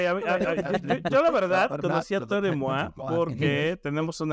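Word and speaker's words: Toro y Moi: Normalmente le decimos Story Moi, que es Toro en Moi.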